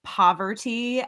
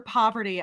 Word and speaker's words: Parvati: I will say, though, famously, her name is actually pronounced Poverty, as we learned in this episode, God forbid Kevin know how to say her name. He did it like four times, called her Poverty.